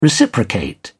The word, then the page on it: reciprocate: British English